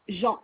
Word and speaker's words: Jean: Jean, JEAN, c'est la régularisation de charges, j'ai cru comprendre.